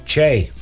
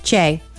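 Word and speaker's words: Chae: Che. – Che.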